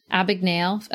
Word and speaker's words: Abagnale: I think of the Frank Abagnale. Am I saying that right? – Abagnale effect.